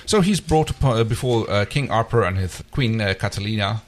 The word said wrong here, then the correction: Catalia: Yes, sorry, Queen Catalia. I mispronounced that.